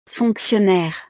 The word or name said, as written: fonctionnaire